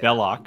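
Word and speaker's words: Belloq: As Indy and Sala are getting the medallion translated, they discover that, I don't know how to say Belloc.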